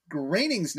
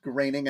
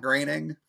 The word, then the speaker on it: Groening: you shot us a message saying that Matt Groening's name is pronounced Groening and not Groening. I don't know which one of us said it, but thank you for that. I believe I knew that, but you know, in my mind, the way his name is spelled, I always want to say groaning and not graining. So just think of it like you're on a farm and you have some grains and we're graining the grains on the farm.